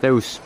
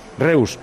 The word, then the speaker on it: Reus: ¿Tú le llamas Reus? ¿Le estamos llamando Reus toda la vida? ¿Cómo se dice? – Reus. – Reus. ¿Reus de verdad? – Sí.